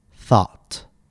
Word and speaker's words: thought: The first pronunciation of O, U, G, H we're going to learn is aw, as in the word thought.